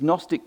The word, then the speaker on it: Gnostic: So you had these Gnostic teachers, that's a silent G, G-N-O-S. It comes from the Greek word gnosis. In Greek, there's no silent letters, so it actually is pronounced gnosis.